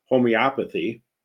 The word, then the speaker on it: homeopathy: This has nothing to do with homeopathy.